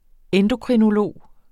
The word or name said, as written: endokrinolog